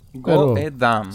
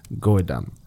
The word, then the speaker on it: Goedam: Goedam. Sí. – G-O-E. D de David, A, M de mamá. Go damn.